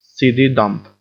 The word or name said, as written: seedy dump